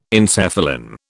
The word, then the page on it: encephalin: British English